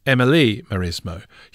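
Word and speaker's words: Amélie Mauresmo: And I'd like to correct my pronunciation and say it was Amelie Maresmo.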